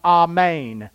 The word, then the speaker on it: Amen: In the original language, that's pronounced Amen. We don't say it that way. We say Amen.